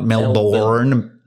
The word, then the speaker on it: Melbourne: Yeah, Melbourne. Not Melbourne. – Mm-hmm. – Even though it's spelled Melbourne. – Melbourne. – Bun. Sure.